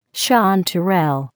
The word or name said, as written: chanterelle